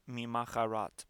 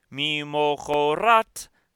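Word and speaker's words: Mi mochorat (ממחרת): Mi mochorat, it looks like mi macharat, but it is pronounced. Mi mochorat ha-shabbat.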